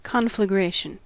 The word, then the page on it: conflagration: American English